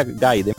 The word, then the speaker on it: Gaiden: No, no, a sabiendas de que Ninja Gaiden, que es así que se pronuncia, el, estaba mencionando Gaiden, pero eso es algo de nosotros.